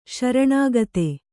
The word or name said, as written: ಶರಣಾಗತಿ